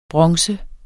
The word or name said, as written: bronze